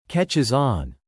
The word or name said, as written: catches on